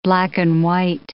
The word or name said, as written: black-and-white